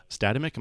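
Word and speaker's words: Statamic: Jack is the founder of Statimic. Am I pronouncing it right? – Boom, nailed it.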